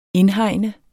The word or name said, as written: indhegne